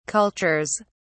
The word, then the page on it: cultures: British English